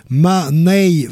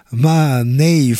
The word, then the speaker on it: Mahne: Musicien australien à l'instant, retenez son nom, c'est difficile peut-être, mais essayez, Mahnay Frame, ça s'écrit M-A-H-N-E, son prénom, Mahnay Frame,